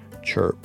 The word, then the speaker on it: CRHP: Then in 1996, I was invited to go on to a men's spiritual retreat that's called CHRP, Christ Renews His Parish, C-R-H-P. They pronounce it CHRP.